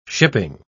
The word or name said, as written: shipping